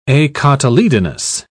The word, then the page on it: acotyledonous: British English